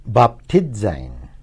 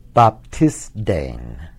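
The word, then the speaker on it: Baptizein (βαπτίζειν): Baptizein, to dip or immerse. Another pronunciation of the Zeta would have it this way. Baptisdein, to dip or immerse.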